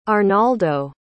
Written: Arnaldo

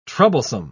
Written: troublesome

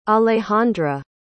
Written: alejandra

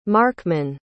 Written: marchman

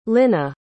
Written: linna

